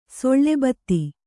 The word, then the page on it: ಸೆಳೆಬೆತ್ತ: seḷe betta